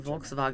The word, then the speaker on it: Volkswagen: Changing the V and the W. – Yeah. – Which actually might be closer to how it's said in Volkswagen.